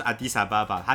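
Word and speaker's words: Addis Ababa: 原文就是就是阿迪萨巴巴，它其实就是阿迪斯，其实是新的意思。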